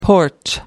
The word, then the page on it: porch: American English